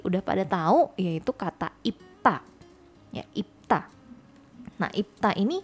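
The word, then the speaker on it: ipta (입다): Kalau di konsonan akhir bunyinya jadi seperti huruf P. Ya, jadi ipta. Tulisannya B, bacanya P ipta.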